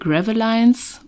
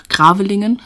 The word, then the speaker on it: Gravelingen: Also der Ort in Frankreich heißt, ich würde das Englisch erstmal aussprechen, Gravelines oder Gravelinen, ähm, auf Deutsch einfach Gravelingen.